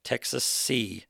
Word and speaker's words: Texasee: Texas and Tennessee put together is spelled Texasee. Texasee, like Tennessee.